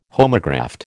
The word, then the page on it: homograft: American English